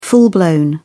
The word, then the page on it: full-blown: British English